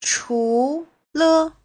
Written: chu2le5

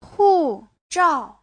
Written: hu4zhao4

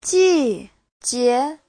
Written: ji4jie2

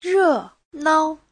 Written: re4nao5

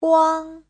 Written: guang1

